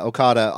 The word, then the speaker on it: Okada: Come on, you got to say it like the Japanese announcers. Kazuchika Okada. No, Okada.